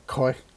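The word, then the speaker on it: Khoi: I think most people pronounce it Nguyen when it's actually closer to what I said, from Koi Barbecue, which is actually Koi Barbecue. It's K-H-O-I Barbecue, Koi meaning smoke in Vietnamese.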